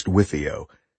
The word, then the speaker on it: WFIO: It's pronounced Wifio.